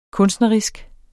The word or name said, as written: kunstnerisk